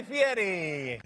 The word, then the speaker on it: Fieri: Ladies and gentlemen, here he is, Guy Fieri.